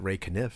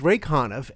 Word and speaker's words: Conniff: Of course, they pronounced it Ray Conniff, but it's Ray Conniff, and it's all singing Christmas songs.